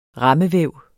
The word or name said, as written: rammevæv